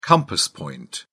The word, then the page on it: compass point: British English